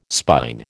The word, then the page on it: spine: American English